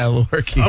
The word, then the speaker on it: Lewerke: Because I can't pronounce that guy's name. Brian Lewerke? Do you know who that guy is? – Yeah, Lewerke. – Okay, Lewerke? Okay, well, good luck to you too, sir.